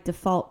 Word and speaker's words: default: I think it's my default podcast for me time. – Default? Not yeah. Default? – Well, wrong. – I think you had the wrong, the emphasis on the wrong syllable.